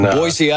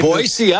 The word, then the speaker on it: Boise: Boise, you had to say it right. Right? – Boise, Idaho, right? – People always say it with a Z, right? – That's right. It's Boise, Idaho. The locals know it's Boise, Idaho.